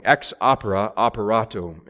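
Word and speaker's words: ex opere operato: You've probably heard the phrase ex opero operata. I said that wrong. Ex opera operato.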